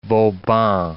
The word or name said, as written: Vauban